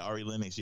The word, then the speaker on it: Ari Lennox: Ari LaRoe, that what you said? What was it? – Oh, yeah, Ari Lennox, yeah.